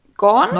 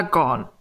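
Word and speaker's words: Gaughan: Nämlich die große Zopfmustersammlung von Nora Gorn. – Gorn? Nora Gorn. Das GH spricht man nicht.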